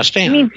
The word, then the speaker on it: Staunton: Yeah, Stanton. Yeah, whatever. It's not pronounced like it's written.